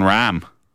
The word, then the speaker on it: Rahm: The name of R-A-H-M correctly. – John Rahm. – Yeah, no, that's incorrect. – Rahm, there you go. – John Rahm. – Yeah. – John Rahm.